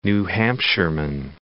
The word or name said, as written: New Hampshireman